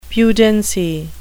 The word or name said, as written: pudency